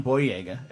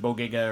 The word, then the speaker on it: Boyega: I, you know, first off, I want to correct something. John Boyega is how you pronounce his name. – Did I pronounce it wrong? – You said Bodega or something like that.